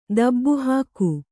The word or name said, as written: ದಬ್ಬುಹಾಕು